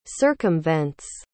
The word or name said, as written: circumvents